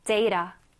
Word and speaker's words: data: data. 네, 한 번 더. data. 네, 잘하셨어요. data 해도 되고 data. T 발음이 있어도 되지만 중요한 거는 앞에 강세가 있다라는 거 기억해 두시면 되겠어요.